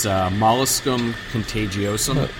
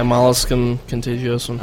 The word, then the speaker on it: molluscum contagiosum: It's molluscum contagiosum. Contagiosum. – Yeah, molluscum contagiosum.